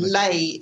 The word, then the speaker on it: Leh: Ladakh. Ladakh is the area, and Leh is the town. – Okay. – The picture on the left, it's got Leh there.